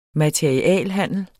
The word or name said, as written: MATERIALHANDLER